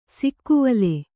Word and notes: ಸಿಕ್ಕುವಲೆ: sikkuvale

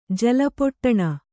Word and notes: ಜಲಪೊಟ್ಟಣ: Kannada

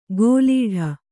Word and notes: ಗೋಲೀಢ: gōlīḍha

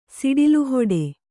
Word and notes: ಸಿಡಿಲುಹೊಡೆ: kannada

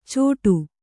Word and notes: ಚೋಟು: cōṭau

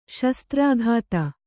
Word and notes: ಶಾಸ್ತ್ರೋಕ್ತಿ: śastrōkti

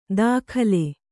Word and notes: ತಗಲೆ: kannada

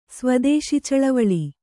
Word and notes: ಸ್ವದೇಶಿಚಳವಳಿ: Kannada

